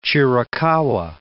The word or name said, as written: Chiricahua National Monument